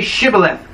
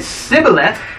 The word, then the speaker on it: Shibboleth: If he replied, "No," they said, "All right, then say Shibboleth." If he said Sibboleth, because he could not pronounce the word correctly, they seized him and killed him.